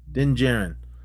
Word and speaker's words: Din Djarin: Din Djarin.